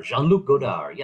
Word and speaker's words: Jean-Luc Godard: Jean-Luc Godard.